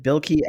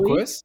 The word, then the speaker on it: Bilquis: Is it Bilkey Ebeli? – Bilquis? – Bilquis? Bilkeys? – Yeah, you got it.